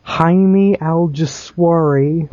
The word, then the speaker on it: Jaime Alguersuari: We have the official replacement of my boy, Sebastian Bourdais, with Jaime Alguersuari. Correct me if I'm saying that wrong. – I think you're pretty darn close.